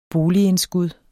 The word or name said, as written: boligindskud